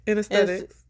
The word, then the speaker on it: anesthetics: Did I say it right? Anesthesia? – Anesthetics. – Okay, anesthetics. – Right. You said it right.